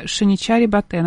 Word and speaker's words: Шиничари Батэ: Тогда одинокий слон по кличке Тхурбе убил родителей мужчины по имени Шиничари Батэ. Надеюсь, я правильно ставлю ударение, если что. – Извините. Ты правильно прочитал.